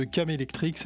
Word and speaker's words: Kamelectric: Vous pouvez le trouver sur le Bandcamp de Cam Electric, ça s'écrit K-A-M-E-L-E-C-T-R-I-C, Cam Electric.